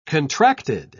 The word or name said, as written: contracted